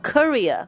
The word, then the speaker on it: courier: Courier, 这那个是不是 C-O-U-R-R-I-E-R? – 对没错。 – 那个叫什么 courier? – Yeah, courier. It almost sounds like the Indian dish.